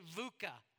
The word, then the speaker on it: VUCA: Everyone say VUCA. It is spelled exactly the way it sounds, or at least the way I think it sounds with my accent. V-U-C-A, VUCA.